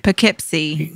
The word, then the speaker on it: Poughkeepsie: Poughkeepsie, New York.